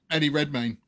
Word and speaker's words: Eddie Redmayne: Is it Redmayne? How do you say his name? – Eddie Redmayne.